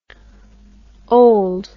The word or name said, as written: olde